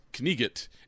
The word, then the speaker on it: knight: So like the actual original pronunciation of the word knight, as in knight in shining armor, is knigget. In Monty Python, where he says, "You silly English knigget," he's actually pronouncing knight correctly for the period, which is like it's funny.